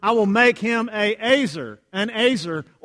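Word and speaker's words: ezer: I think this is NIV, NLT also has helper, but it's actually the Hebrew word, and it's E-Z-E-R, and it's pronounced Azer.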